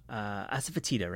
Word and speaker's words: asafoetida: I want to talk about asafoetida, right? Hing? Did I say that right? – Yeah, you said it just right.